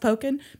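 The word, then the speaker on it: Pokin: I know it's pronounced Poken because he has a column called Poken Around.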